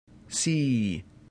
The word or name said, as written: si